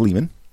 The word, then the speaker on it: Lehmann: So after RXVT was written, a gentleman named Mark Lehman, link in the show notes so you can determine how to pronounce that name, but I'm going to go with Lehman.